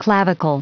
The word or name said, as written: clavicle